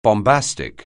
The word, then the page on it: bombastic: anglais